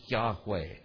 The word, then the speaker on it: Yahweh: And we're not sure how it sounds, yich, yich, or maybe we thought it might be Yahweh. Okay. And so we pronounce it Yahweh.